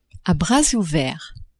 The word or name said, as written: à bras ouverts